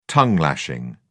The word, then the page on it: tongue-lashing: British English